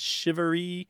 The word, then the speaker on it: charivari: It's chivalry. How would you spell that? – I've seen it C-H-A-R-I-V-A-R-I, but it's pronounced like S-H-I-V-A-R-E-E.